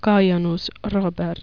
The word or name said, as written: kajanus robert